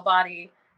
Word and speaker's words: BOTI: We should say BOTI is how Body is spelled.